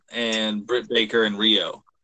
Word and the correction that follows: Riho: Riho. – See, I fucked it up.